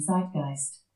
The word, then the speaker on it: zeitgeist: The zeitgeist, German pronunciation zeitgeist, is a concept from 18th to 19th century German philosophy, meaning spirit of the age or spirit of the times.